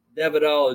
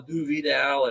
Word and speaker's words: de Vidal: It's a French name, and I've heard people say Devidal or Du Vidal, and I just don't call me late for dinner.